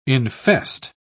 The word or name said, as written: infest